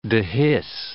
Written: dehisce

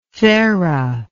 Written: thare

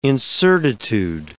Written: incertitude

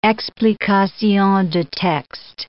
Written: explication de texte

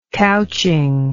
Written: couching

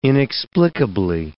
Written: inexplicably

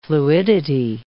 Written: fluidity

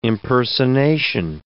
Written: impersonation

